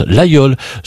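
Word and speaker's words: Laguiole: Ça s'écrit l'Aguiol, mais on prononce l'Aïeul.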